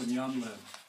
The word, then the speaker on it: nyamlen: Oh, nyanlen. – Yeah. – How do you spell that? – N-Y-A-M-L-E-N. – Nyanlen. – Nyanlen.